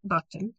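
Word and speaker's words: Buckton: Yeah, I'm on Amazon under Kerri Merriam Buchton.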